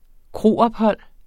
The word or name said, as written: kroophold